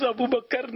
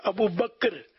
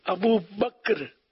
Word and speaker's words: ابو بکر: اصل لفظ ابو بکر نہیں ہے، جو صحیح تلفظ ہے ابو بکر، کاف جو ہے وہ ساکن ہے، کاف پر جزم ہے ابو بکر.